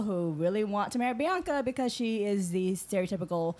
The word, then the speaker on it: Bianca: Bianca. – The long A.